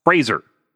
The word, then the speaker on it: Fraser: That's how it's pronounced. Fraser, like laser.